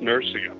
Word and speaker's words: Nursia: Well, Nathan, you pronounced it correctly. It is Nursia, by the way. Although today the same town is still there in the Italian mountains, but they call it Norcia, N-O-R-C-I-A.